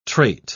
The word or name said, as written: trait